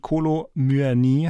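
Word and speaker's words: Kolo Muani: Urs Fischer, Martina Knief und der Kollege Tim Brockmeier im Trio und Kolo Myani heißt er übrigens, habe ich wieder falsch gesagt, Entschuldigung, lernen wir noch.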